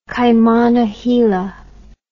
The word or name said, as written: Kaimana Hila